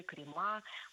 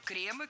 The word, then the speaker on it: кремы: Крема, неверно. Вспоминаем слово «благо», «благом», «благами». И здесь все под копирку. Кремы, кремов, кремах. Ударение сохраняем на первом слоге во всех формах.